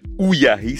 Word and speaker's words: Uyai: qui s'appelle Ouyahi. Ça s'écrit U-Y-A-I, et c'est sur France Inter ce soir.